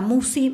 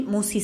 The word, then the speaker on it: Musci: El canal lleva mi nombre, Marisa Musi. Musi se escribe M-U-S-C-I latina.